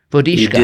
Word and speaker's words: Vodicka: Vodicka. You yeah, you did a fine job. You sound like a, a native Czech speaker.